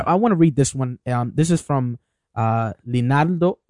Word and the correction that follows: Leandro: right? Mm-hmm. Did I say that right? Pelas. – Oh, it's Leandro.